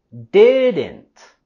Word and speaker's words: didn't: Y lo vamos a subir igual en la primera sílaba de didn't. Didn't. No le vamos a expulsar el aire totalmente a decir didn't, sino solamente con la stop T con didn't.